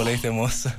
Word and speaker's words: Leite Mossa: Ciao, no, la pronuncia era perfetta, invece, di solito la sbagliano tutti, invece è perfetta, proprio Leite Mossa.